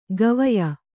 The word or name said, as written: ಗವಯ